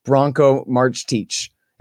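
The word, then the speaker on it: Marcetić: His name is Bronco Marchteach. It's spelled like Marcetic, sort of, kind of, Branko Marcetic.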